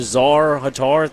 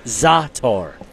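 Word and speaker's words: za'atar: Also, I was given the proper pronunciation for the Czar Hatar thing. – Yep. – It's Zatar. Zatar. She said I was close.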